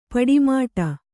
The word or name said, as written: ಪಟ್ಟೆಮಡಿ